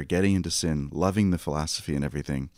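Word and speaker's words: Sinn: I always pronounce it Sin, and I feel like I should probably pronounce it the German way, but I'm just going to say Sin.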